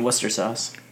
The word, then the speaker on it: Worcestershire: Worcestershire sauce. – You mean Worcester sauce? – Worcestershire.